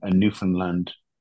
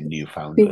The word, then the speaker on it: Newfoundland: I used to say Newfoundland. – Newfoundland, You don't you say Newfoundland. I'm probably not even saying it right now, but anyway, something like Newfoundland.